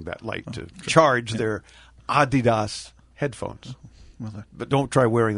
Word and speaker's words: Adidas: Sneaker folks, Adidas, which is actually, having been to Germany every year for 20 years covering IFA, is Adidas, is the correct pronunciation.